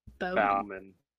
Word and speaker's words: Baumann: B-A-U-M-A-N-N. – Bowman.